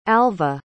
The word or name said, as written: alvah